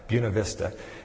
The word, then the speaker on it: Buena Vista: And that's how they pronounce it, by the way. It's not just bad Spanish. That's the way they pronounce it in Colorado, Buena Vista.